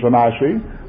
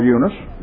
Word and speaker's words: Eunice: The old British pronunciation's an IC. We call her Eunice.